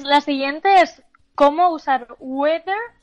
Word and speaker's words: whether: Weather, también antes de nada hay que tener cuidado con el spelling, con la forma de escribirlo, porque hay muchas veces suena igual que weather de tiempo, pero significa sí, o sea, un sí no afirmativo. Mm. Se escribe W-H-E-T-H-E-R, mm, weather. weather.